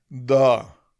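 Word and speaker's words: Dao: El primero se llama wu wi y el segundo es de, se pronuncia da, o sea, da, es la pronunciación de la segunda parte,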